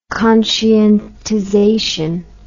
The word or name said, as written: conscientization